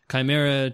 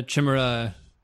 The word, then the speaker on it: Chimera: And I looked it up, and there are apparently a few different ways of pronouncing it. I think we were kind of messing around with it at the end of last show. Chimera, Chimera, I think are both acceptable.